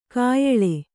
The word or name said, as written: ಕೋಯಿಲು